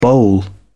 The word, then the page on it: boll: British English